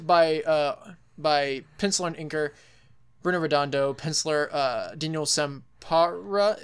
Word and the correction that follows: Sampere: Is that how you pronounce his name? – I usually say Sampere. – Sampere. That sounds better.